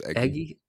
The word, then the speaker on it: Egui: it's EGUI? Do you know how to pronounce this person? – It's Eggy. – Eggy, okay, Eggy Rosario, yeah.